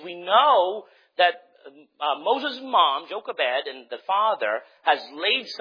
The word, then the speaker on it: Jochebed: Hebrews 11, verse number 24 to 26, talking about Moses and his mother, how would you pronounce this? J-O-C-H-E-B-E-D, Jochebed, right? That's what I would say, Jochebed, all right?